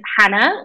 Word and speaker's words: Jana: Easiest place is Hannah, which is spelled J-A-N-A. You wouldn't expect that, but I'm half Spanish, half English, and my parents made my name up.